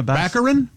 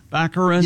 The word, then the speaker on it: Baccarin: Daredevil's girlfriend, Morena Baccarin. – Baccarin.